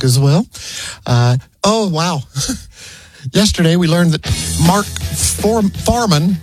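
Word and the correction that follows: Fuhrman: how do you say his name? Farman, that guy from the O.J. Simpson trial. – Yeah, the cop, right? – Yeah, he died. – Mark Furman. Yeah.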